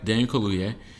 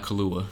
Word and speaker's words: Kaluuya: Daniel Kaluuya, not Kalua.